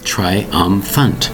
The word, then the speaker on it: triumphant: Triumphant. That's right. Three distinct syllables. Triumphant. Spelled T-R-I-U-M-P-H-A-N-T. It might seem strange. The P-H has the F sound.